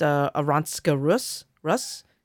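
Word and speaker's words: Arantxa Rus: against Aronska Rus. Yes, that's how it's pronounced.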